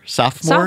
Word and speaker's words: sophomore: Do you say sophomore? – You say sophomore? – I say sophomore, yeah. – Sophomore. I've never heard that before. Huh. Sophomore. – So second sophomore. – O is silent for you, huh? – Yeah, sophomore.